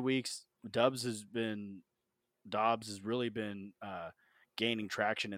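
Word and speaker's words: Doubs: Rome also wants you to take a look at Romeo Dubs. I think it's Dubs, isn't it? Even though it's spelled Dubs.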